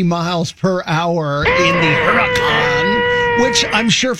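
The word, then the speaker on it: Huracán: I bring it up because a dude in story number one went to one of those places, and he got the Lamborghini Huracan. – Sounds right. – Pronouncing that correctly.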